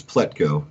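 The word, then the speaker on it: Plutko: I'm probably going to butcher his last name, Pletko. – Oh, yeah. – You nailed it. It's Pletko.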